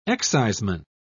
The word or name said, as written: exciseman